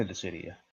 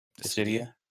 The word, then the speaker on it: Dissidia: It would be nice to see him in Dissidia, you know, but... – He's never been in a Dissidia.